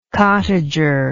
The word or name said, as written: cottager